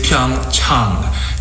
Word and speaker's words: Pyeongchang: The correct pronunciation is Pyeongchang. Chang. Like when you go see your doctor. – Pyeongchang. – A little bit of a drawn out A.